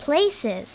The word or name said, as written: place